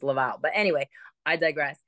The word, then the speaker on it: Laval: It's Laval, Quebec.